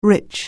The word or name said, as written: rich